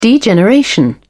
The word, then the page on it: degeneration: British English